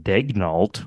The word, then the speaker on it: Daigneault: And he just pronounces it Daigneault, which is how any Oklahoman would pronounce it, although he's not an Oklahoman.